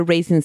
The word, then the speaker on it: Raisin: Yeah, like raisin. – Like raisin, like raisins. A raisin. – Yeah. – The ones you eat.